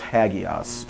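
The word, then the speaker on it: Hagios (ἅγιος): Hagios is and it's a rough breathing, so it's Hagios. If we pronounce it in English, we would say Hagios.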